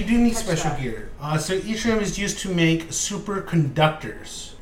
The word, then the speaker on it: Yttrium: Yttrium is the... I think it's, that's what it's pronounced. It starts with an Y.